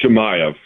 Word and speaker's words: Chimaev: And I think the next big pay-per-view includes Hazmat Chimaev, or however you pronounce his name, against Chimaev, yeah.